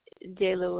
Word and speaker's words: Jaela: Jayla Williams. – Can you spell that for me? – J-A-E-L-A.